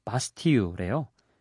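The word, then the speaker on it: Bastille: 이게 프랑스식으로 발음하면 바스틸이 바스티유래요. 그런데 공식적으로는 바스틸이라고 발음한다고 합니다.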